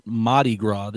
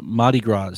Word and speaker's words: Mardi Gras: So going back to what I was saying, first of all, in Mobile, they call it Mardi Gras. They don't pronounce the R. It's like pronounced like an H.